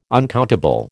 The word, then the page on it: uncountable: American English